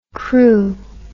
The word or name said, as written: Crewe